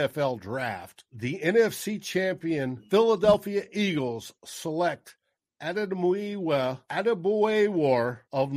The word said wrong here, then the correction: Adebawore: So Adebawore, not so not bad, not too not yeah, too not bad, bad at all. – Adebawore.